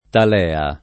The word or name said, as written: talea